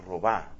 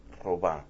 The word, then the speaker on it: Robin: Marta Robá se pronuncia ese nombre. Se escribe Robin. R-O-B-I-N. Marta Robá.